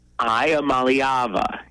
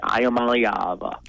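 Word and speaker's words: Iamaleava: They break it down phonetically for you. And it's E-E at the beginning, and it throws my brain through a loop. – Iamalavea is what Matt LePay said. Iamalavea.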